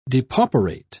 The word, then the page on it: depauperate: British English